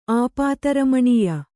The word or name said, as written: ಆಪಾತರಮಣೀಯ